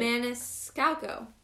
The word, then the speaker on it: Maniscalco: Anyways, the author is Carrie Maniscalco. – you did not say that right. – Maniscalco.